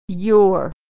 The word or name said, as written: your